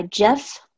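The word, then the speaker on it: Jeffe: And once again, this is The Orchid Throne, Forgotten Empire, Book 1, by Jeff, with a silent E, Kennedy, and this book comes out on September 24th.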